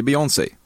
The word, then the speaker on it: Beyoncé: Du vet inte vilket av dessa två uttal som egentligen är det rätta. – Beyoncé eller Beyoncé? – Beyoncé? – Nej. Det är Beyoncé.